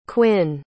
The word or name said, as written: quinn